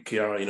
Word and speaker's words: Ciara: and it was Storm Ciara, or Ciara, as they pronounce it over there, at the time.